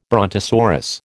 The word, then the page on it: brontosaurus: American English